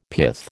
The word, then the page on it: pith: American English